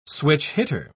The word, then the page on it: switch-hitter: British English